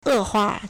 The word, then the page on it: 恶化: 惡化; èhuà